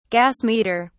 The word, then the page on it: gas meter: British English